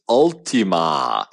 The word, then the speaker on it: Ultimae: Okay, no, it's not Altima. It's Ultima, and it's spelled like with Latin, like the Ultima meaning the last one.